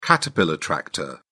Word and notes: caterpillar tractor: British English

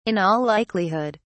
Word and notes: in all likelihood: British English

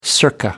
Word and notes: circa: American English

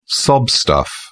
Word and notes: sob stuff: British English

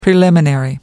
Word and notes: preliminary: American English

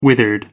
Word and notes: withered: American English

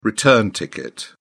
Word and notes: return ticket: British English

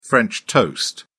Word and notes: french toast: British English

